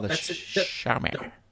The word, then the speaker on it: Xiaomi: Xiaomi, is it how you say it? The Xiaomi phone that you've got? – Xiaomi.